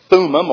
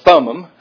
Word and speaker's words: Thummim: The Urim and the Thummim, or Thummim, however somebody wants to pronounce it.